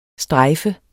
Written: strejf

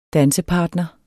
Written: dansepartner

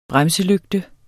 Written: bremselygte

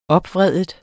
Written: opvride

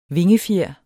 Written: vingefjer

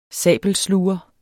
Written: sabelsluger

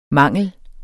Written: mangel

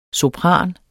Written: sopraner

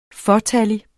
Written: fåtallig

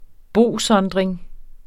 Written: bosondring

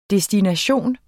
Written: destination

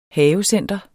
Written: havecenter

